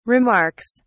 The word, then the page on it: remarks: British English